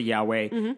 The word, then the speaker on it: YHWH: But basically we pronounce it Yahweh, and Jewish people don't say it because you're not supposed to say God's name.